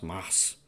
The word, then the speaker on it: Mads Mikkelsen: When I look at Mass, by the way, his name is pronounced Mass. Mads Mikkelsen.